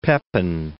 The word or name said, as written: Pepin III